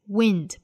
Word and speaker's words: wind: Wind, 包含短音松音的 e, wind, wind.